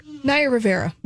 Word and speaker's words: Naya Rivera: Is going to perform Anaya Rivera. Is that Rivera? How do you say her name? – Naya Rivera.